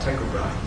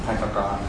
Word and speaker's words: Tycho Brahe: Just wondering if you know how to pronounce that. – Tycho Brahe. – Tycho Brahe. Okay.